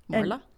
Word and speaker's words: Morla: Morla.